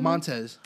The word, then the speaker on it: Montez: Montez.